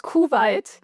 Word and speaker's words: Kuwait: Kuwait.